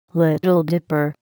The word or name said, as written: Little Dipper